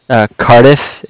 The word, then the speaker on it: Cardiff: Bank of Curtis? – Cardiff. It's spelled C-A-R-D-I-F-F, like Frank Frank.